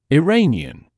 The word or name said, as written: Iranian